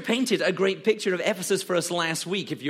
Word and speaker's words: Ephesus: Now I say it, it sounds silly, but the correct pronunciation is Ephesus, not Ephesus, but Ephesus.